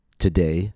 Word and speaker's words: today: T-O-D-A-Y. Today has two syllables. The second syllable is stressed. Notice how the vowel letter O in the first syllable is reduced to a. Today. I'll do that slowly. Today. It's not pronounced today, but today.